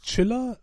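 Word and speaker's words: Tschiller: Und überlegst dir, Chiller. Kein Mensch kann das aussprechen, T-S-C-H. – Ja, ich glaube, es soll wie Chiller, also im Sinne von Chillen, Chillen, äh, rüberkommen, ja.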